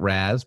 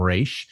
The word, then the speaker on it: Ra's al Ghul: I grew up pronouncing it Raz, but the TV Arrowverse calls him Ra's. Supposedly it means head of the demon in Arabic. So I asked my Lebanese friend how head is pronounced in Arabic. It's basically like Raz, but the R is rolled and the Z is an S. So Ra's.